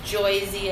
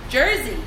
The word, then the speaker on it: Jersey: I lived in Ohio for 4 years and then moved back to Joysy, as everyone likes to say. – Joysy. – But that's not how we say it. – How do you say it? – Jersey. – Oh. – There's an R.